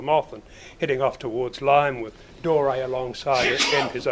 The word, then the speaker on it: Lympne: it's pronounced Lyme, but spelt L-Y-M-P-N-E, Lyme on the Channel Coast in Kent, and this was the preferred setting up point for all the long distance flyers.